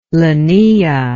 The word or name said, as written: lenaea